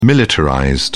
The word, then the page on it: militarized: British English